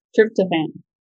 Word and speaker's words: tryptophan: I mispronounced it in the last one. – Tryptophan. – Tryptophan. No, I think it sounds quite different.